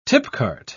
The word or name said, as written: tipcart